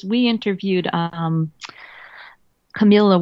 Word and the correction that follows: Carmela: Walgreen, who's a mental health advocate. – Carmela. – Carmela. I always say that wrong.